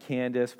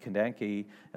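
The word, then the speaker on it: Kandake: And there was an Ethiopian, a eunuch, a court official from, we pronounce it Candace, but it was probably actually technically pronounced Kandanki.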